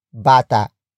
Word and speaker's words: bata: And that noun is bata. Bata. Bata. Bata is spelled as B-A-T-A.